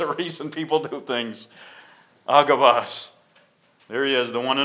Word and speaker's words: Agabus: "And as we tarried there many days, there came down from Judea a certain prophet named Agabus." That's the correct pronunciation, Agabus.